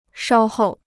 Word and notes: 稍后: shāo hòu

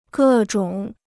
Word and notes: 各种: gè zhǒng

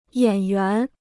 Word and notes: 演员: yǎn yuán

